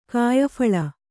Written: ಕಾಯಫಳ

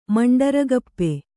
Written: ಮಂಡರಗಪ್ಪೆ

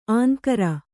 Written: ಅಂಗರ

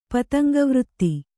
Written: ಪತಂಗವೃತ್ತಿ

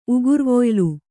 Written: ಉಗುರ್ವೊಯ್ಲು

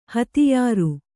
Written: ಹತ್ಯಾರ

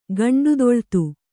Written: ಗಂಡುದೊಳ್ತು

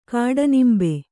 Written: ಕಾಡನಿಂಬೆ

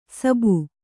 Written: ಸಬ್ಬು

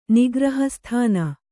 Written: ನಿಗ್ರಹಸ್ಥಾನ

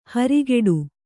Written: ಹರಿಗಡಿ

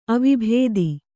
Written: ಅವಬೋಧೆ

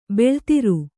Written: ಬೆಳುದುರೆ

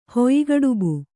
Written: ಹುಯ್ಗಡುಬು

